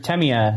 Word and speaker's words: Tamiya: Mr. Tamiya. That's how he says his name.